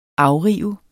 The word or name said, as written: afrive